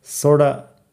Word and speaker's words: sorta: Sorda, como americano no pronuncio fuerte la T, sorda, S-O-R-T-A.